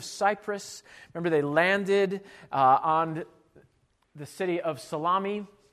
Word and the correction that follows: Salamis: or Salamis, as it's better pronounced, on the eastern side of the island.